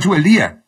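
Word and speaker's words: Zubeldía: Pero yo sé que hablamos de Subeldía con tilde en la I, como se pronunciaba antiguamente, porque ahora los relatores le dicen Subeldía.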